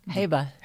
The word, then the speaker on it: Hewa: And in particular, I wanted to talk about, and I'm not sure how I'm pronouncing this correct, Hua? the Heba. – Heba. Wow. – Yeah. – Well, it's spelled H-E-W-A, so yeah.